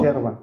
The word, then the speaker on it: yerba: Es yerba porque no es h HI, se escribe con Y. Y nosotros a la Y la pronunciamos SH, yerba. Si fuera HI, diríamos hierba, Hierba, pero esto es hierba porque es con Y, así, la, hierba, el mate, y bueno, esto que esto se llama bombilla, es un popote que tiene un filtro abajo.